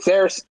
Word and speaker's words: Saoirse: I think it's pronounced Cersei, but it's spelt with like Saoirse Ronan. You talking about Saoirse Ronan? – Cersei.